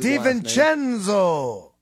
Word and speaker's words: DiVincenzo: They have the ball, and they get called for a moving screen, or what some people thought was a flop by Dante DiVincenzo, or however you pronounce it. – DiVincenzo.